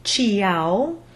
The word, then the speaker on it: ciao: Like Felice said, think of the word ciao, which is spelled C-I-A-O. But we don't say ciao. We ignore the I and say ciao. Phonetically, you'd spell this T, the long squiggly S, bright A, and closed O.